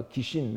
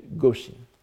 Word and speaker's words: goshin: Il faut, le, le, sono doit être prononcé non pas kishin, mais goshin.